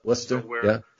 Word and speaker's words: Worcester: Worcester, gotta say it right.